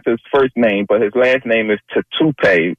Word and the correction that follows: Tatupu: or something. I believe he was Lofa Tatupu. – Yeah.